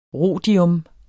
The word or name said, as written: rhodium